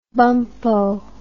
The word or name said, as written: bumppo